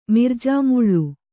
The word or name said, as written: ಮಿರ್ಜಾಮುಳ್ಳು